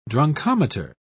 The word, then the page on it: drunkometer: British English